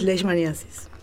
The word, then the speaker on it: leishmaniasis: Hantavirosis, leptospirosis, leishmaniasis.